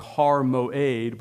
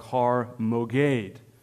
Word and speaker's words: Har Moed: It's more often pronounced something like Har Moed, but there are times when it is transliterated into Greek, or at least the letters are transliterated in a way that is like Har Moged, Har Mogedon.